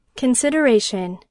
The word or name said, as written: consideration